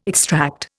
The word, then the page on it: extract: Indian